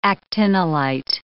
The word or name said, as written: actinolite